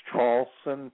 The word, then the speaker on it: Traulsen: Last name is Traulsen, T like Tom, R A U L S E N like Nancy.